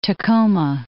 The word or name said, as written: Tacoma